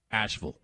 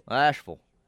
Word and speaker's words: Asheville: When you give a city in the South whose uh, city name ends in V I L L E, TJ, you got to pronounce it like V U L. Asheville. Asheville.